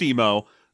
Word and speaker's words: Emmo: It's spelled E-M-M-O, but it's pronounced Emo.